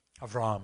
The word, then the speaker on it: Avram: And the next major story, major character after Noah's family, the next major character is going to be Abram. Avram. If you want to put a little more Hebrew pronunciation in there, or is that Yiddish?